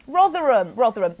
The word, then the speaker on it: Rotherham: This has come from the Rotherham? How do you say that? – Rotherham.